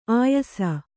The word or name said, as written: ಆಯಾಸ